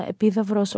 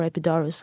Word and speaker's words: Epidaurus: And I would also add to that Mycenae, definitely, and the famous Lion Gate, and also Epidavros or Epidaurus, how you want to pronounce it.